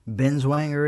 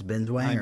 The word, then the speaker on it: Binswanger: Did I get his name right yet? – Binswanger it is. – It is? – It's Binswanger.